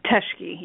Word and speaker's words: Teschke: How do you say your last name, Teschke? – Teschke, yeah, that's correct.